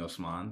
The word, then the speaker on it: Osman: You've said that that's fine, but your name is not actually pronounced Osman. I just want to be clear for anyone listening that thinks that I'm pronouncing it wrong.